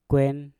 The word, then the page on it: kwen: Mpur